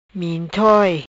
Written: Mintoi

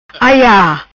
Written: Aia

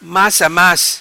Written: Mas a mas

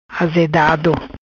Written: Azedado

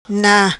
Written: Na

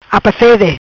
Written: Apafede

